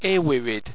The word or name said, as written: awirid